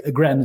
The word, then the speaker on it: Gren: It's a Norwegian word. So I'm thinking that if I'm not pronouncing it correctly, then it doesn't stand a chance. Gren is fine.